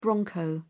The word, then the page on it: bronco: British English